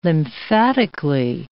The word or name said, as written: lymphatically